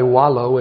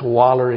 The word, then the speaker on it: wallow: Or should we say wallow if we want to be correct in our pronunciation? But if you're in Alabama, it's waller in your own mire.